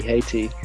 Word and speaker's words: Haiti: Haiti, Haiti, even. That's how you pronounce it, isn't it? Is it Haiti? Yeah. Yeah. Good.